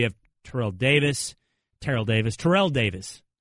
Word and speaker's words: Terrell: Man, see, Ter- Terrell Owens came in and threw off the pronunciation. Everybody. Yes. I agree. – Terrell Davis. It was always Terrell Davis until Owens came on.